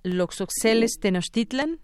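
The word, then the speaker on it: Loxosceles tenochtitlan: Bueno, pues ya ya está ya hoy eh, aprendimos más de esta Loxoxeles Tenostitlan Espero haberlo pronunciado bien, doctor. Así es.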